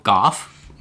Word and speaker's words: Gough: He also explained the proper way to pronounce Michael Goff. I have just done that. Michael Goff. – Michael Goff. – G-O-U-G-H.